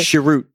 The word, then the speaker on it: Chirrut: Chirrut.